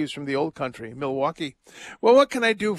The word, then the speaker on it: Milwaukee: If you know Milwaukee, you don't say Milwaukee. It's Milwaukee, right, Ron? – Yes, it's Milwaukee.